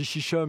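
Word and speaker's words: Xixa: Alors je dis Chicha, mais en fait ça s'écrit X-I-X-I-X-A.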